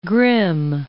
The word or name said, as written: Grimm